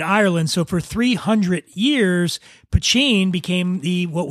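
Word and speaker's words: Poitín: And when I say Poteen, it's spelled P-O-I-T-I-N, Poteen.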